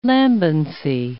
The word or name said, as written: lambency